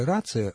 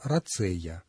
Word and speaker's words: рацея: Только ударение. Не рация, а рацея.